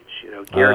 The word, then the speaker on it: Goethe: Goethe. – Oh, yeah. – Spelled Goethe. People often don't know how to pronounce that name.